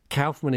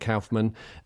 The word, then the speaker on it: Kaufman: Kaufman is, I mean, it's a German name by origin, yeah. So you'd think Kaufman. A lot of people in America pronounce it Kaufman. There's a David Letterman appearance where Letterman pronounces it both ways.